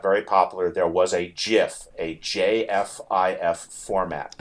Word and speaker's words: GIF: But then they also say same reason GIF is a hard G, because G stands for graphics. Hard G, which is my reasoning.